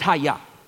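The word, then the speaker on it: haya (חיה): The quicken is a Hebrew word, and this is how it's pronounced. It's haya.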